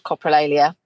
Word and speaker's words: coprolalia: And I mean, also, all your kids have developed coprolalia. I hope I've pronounced that correctly. – Coprolalia. – There we go.